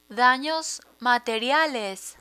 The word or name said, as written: danos materiales